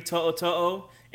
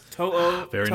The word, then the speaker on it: To'oto'o: Henry To'oto'o, and that's how you pronounce it, folks. To'oto'o. Nice. It is. Listen, I think people get sped up and it starts sounding like To'oto'o. It's just To'oto'o. It's not that hard.